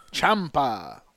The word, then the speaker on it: Ciampa: Ciampa.